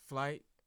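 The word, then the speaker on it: Flite: It's Flight Genius.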